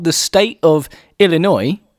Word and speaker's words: Illinois: That's how it's said. Illinois. – You sound so... Illinois.